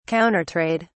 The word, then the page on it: Countertrade: British English